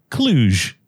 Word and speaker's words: Kluge: Kluge.